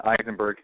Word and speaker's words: Eisenberg: It's Brian with a Y, so B-R-Y-A-N. And then Eisenberg, E-I-S-E-N-B-E-R-G.